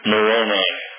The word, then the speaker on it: Moroni: If you have an angel macaroni that tells you something, no, that's the wrong pronunciation, Moroni, we used to call them macaroni all the time when we were kids.